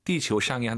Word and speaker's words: 地球: 地球上이 한 단어가 아니라 地球 한 단어, 上面 한 단어입니다. 치유하지 마시고 아유 사이에 오 넣어서 지구 발음 다시 한번.